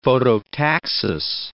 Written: phototaxis

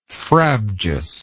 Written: frabjously

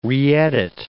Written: reedit

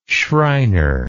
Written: schreiner

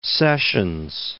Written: Sessions

